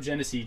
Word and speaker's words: Genesee: If you don't know Genesee, Genesee is G E N E S E E, which G-C. I don't for me, like when you hear it pronounced, like that's not how I would spell it.